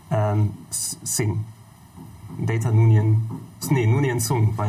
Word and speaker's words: Data: Ich, ich, dachte da, ich dachte, Data heißt Delta, weil ich konnte das nicht richtig raushören von der Aussprache von denen und Delta ist Wie heißt für mich... denn wirklich? – Data.